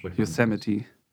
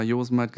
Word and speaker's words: Yosemite: Ja, Yosemite. Ähm, ja, und alle anderen haben sie mal Josemite gesagt, okay, alles klar.